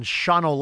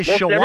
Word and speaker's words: Shawano: I greatly appreciate you coming to the program while you are enjoying your family and fishing bass on Shawano Lake in eastern Wisconsin. – You even said it correctly. – I used to say Shawano. – Everybody says Shawano.